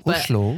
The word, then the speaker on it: Oslo: Technically, it's Oslo. Oslo.